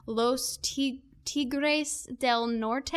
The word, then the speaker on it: Los Tigres del Norte: And your dad, as you know, a pretty, not pretty, but famous musician, I want to make sure that I say this right, Los Tigres del Norte, mm-hmm. Is that right? – Yes.